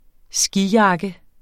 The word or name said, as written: skijakke